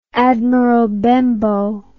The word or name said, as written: admiral benbow